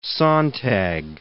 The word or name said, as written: sontag